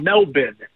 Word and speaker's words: Melbourne: I flew down to Melbourne, as a matter of fact, or Melbourne, as the locals pronounce it,